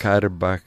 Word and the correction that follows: karkaba: karkaba, the darbuka, the fretless banjo.